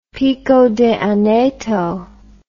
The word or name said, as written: Pico de Aneto